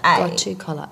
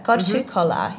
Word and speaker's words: gotu kola: G-O-T-U. And then kola is K-O-L-A. – Gotu kola. Okay, got it. – Gotu kola, yeah.